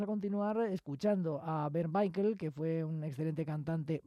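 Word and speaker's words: Weikl: Y ahora vamos con un cantante que es precisamente miembro honorario de la Ópera de Viena, el austriaco, el vienés, Bernd Baikl, un barítono que acaba de cumplir 80 años, ya que nació el 29 de julio de 1942. El apellido es W-E-I-K-L, y ellos pronuncian Baikl.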